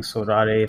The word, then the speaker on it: Sorare: Yeah, I kept calling it Sorare and then someone told me it's so rare. And I was like, oh yeah, that makes sense actually.